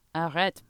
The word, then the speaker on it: Arrête: How do you say it in French? Arrête. Arrête? – Yeah. Arrête. Perfect. Arrête.